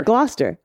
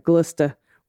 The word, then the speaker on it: Gloucester: pronounce the last three with a st instead of a stir on the end. Glister, Worcester.